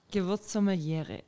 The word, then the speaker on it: Gewürzsommelière: Gewürzsommeliere.